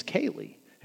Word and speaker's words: Kayleigh: And in the song, the girl's name is Kaylee, and it's spelled K-A-Y-L-E-I-G-H.